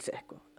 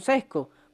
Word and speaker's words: Csko: Daniel Sesco. Todavía es complicado porque la gente, coño, Sesco. ¿Cómo se escribe esa vaina, vale? ¿Cómo, Sesco? cuesta, ¿oíste? Mardito, o sea, utiliza el cerebro. C-S-K-O.